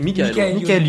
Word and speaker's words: Michael: C'est un trio composé de Michel, je sais pas comment ça se prononce, Michael ou Michel. – Michael